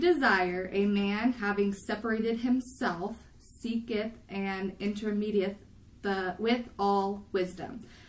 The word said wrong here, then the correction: intermeddleth: So, intermeddleth. Is that how you say it, Mita?